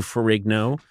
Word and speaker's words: Ferrigno: I didn't suddenly go from Bill Bixby to Lou Ferrigno. That's not what happened. – Lou Ferrigno. The GN makes like a nya sound, like gnocchi.